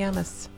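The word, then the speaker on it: Llamas: Oh, yeah, Tommy Yamas. – Yamas, is that how you say his name? There's a bunch of L's that you don't pronounce in the beginning of his name. Yamas.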